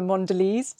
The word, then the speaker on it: Mondelez: I mean, Ella, you are a brilliant brand ambassador for Mondelez.